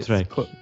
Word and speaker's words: Kortrijk: Kortrijk.